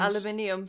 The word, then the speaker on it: aluminium: And aluminium for if you speak English. Yeah, aluminium if you're American. – It's spelled... Oh, sure. They're in Washington. It's spelled like aluminium here, but they're in America. That's what I'm like... – Yeah. Oh, they do drop the I.